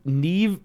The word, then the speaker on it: Niamh: No, it might be Neve because it's spelled N-I-A-M-H is both the actress's name and I believe the character's name as it should be spelled.